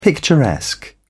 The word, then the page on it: picturesque: British English